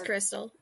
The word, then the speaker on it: Crystal: It's just Crystal. Like, it's the very simple kind of Crystal. Okay. – Way of putting it. It's just spelt funny because my aunt was trying to,